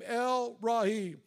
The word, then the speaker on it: El Roi: But this morning I want to share with you a descriptive term, a revelatory name of God that was used by Hagar, the name El Rahi. It's spelled E-L-R-O-I.